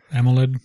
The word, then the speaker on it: AMOLED: AMOLED. – Yes, yes, there you go. That's how you say it.